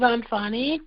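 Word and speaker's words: Anfaani: This is Anfani from L.A.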